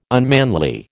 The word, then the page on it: unmanly: American English